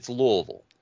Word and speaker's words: Louisville: But yeah, it's Louisville. If you live there, you have the right to tell people how to pronounce where you live.